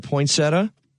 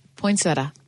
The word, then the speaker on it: poinsettia: How do you pronounce it? – Poinsettia. – You know what? I do my little Chicago slang. I say poinsettia, but I know it's spelled with an I-A at the end.